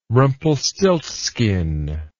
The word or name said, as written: rumpelstiltskin